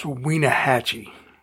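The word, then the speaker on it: Wenatchee: And I believe it's pronounced Weenahatchee.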